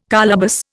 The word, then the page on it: colobus: Indian